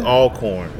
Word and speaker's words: Alcorn: It's Alcorn. It's not Alcorn. It's Alcorn.